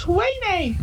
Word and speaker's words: Ouaisne: And we live next to a place that's spelt O-U-A-I-S-N-E. But it's pronounced Huayne.